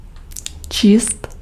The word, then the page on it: lire: /liʁ/; France